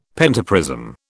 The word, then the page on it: pentaprism: British English